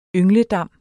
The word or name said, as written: yngledam